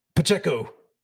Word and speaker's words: Pacheco: And I'm going to butcher the last name Isaiah Pacheco.